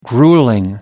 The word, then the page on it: grueling: British English